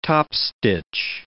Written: topstitch

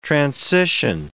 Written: transition

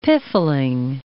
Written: piffling

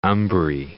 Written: umbrae

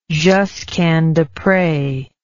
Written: Josquin Despres

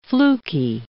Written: flukey

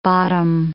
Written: bottom

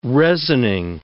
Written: resining

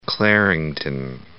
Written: Clarington

